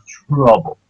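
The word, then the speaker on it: To Love-Ru: It's trouble. No, really, it's pronounced trouble.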